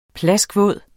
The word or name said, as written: plaskvåd